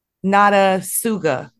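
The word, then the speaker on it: Notasulga: So she was born January 7th, 1891, the fifth of eight children born to John and Lucy Ann Hurston in Notsalaga. I'm saying it wrong. Notasuga, Alabama.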